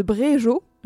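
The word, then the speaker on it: Bréjeot: où l'artiste invité c'était Le Comte de Bréjo, j'espère que je prononce correctement, G-E-O-T, ça se prononce Jo.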